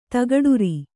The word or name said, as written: ತಗಡುರಿ